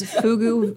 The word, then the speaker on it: Fugu: It is Fugu vodka. And I think I'm saying that right. F-U-G-U. Yeah. Fugu. – F-U-G-U. – Yep. Fugu. – F-U-G-U. Oh, my God. O-B-G-Y-N.